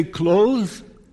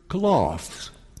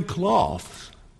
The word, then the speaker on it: cloths: Do we say clothes? I also heard cloths. She wrapped him in swaddling cloths. I think the word cloth, the pronunciation cloth, is probably better in this case, right? Because it doesn't make any sense to wrap someone in clothes. You dress someone in clothes. You don't wrap people in clothes.